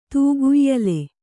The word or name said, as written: ತೂಗುಯ್ಯಾಲೆ